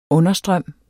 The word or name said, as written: understrøm